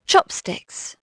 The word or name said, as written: chop